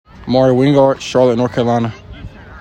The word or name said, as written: Mari Wingard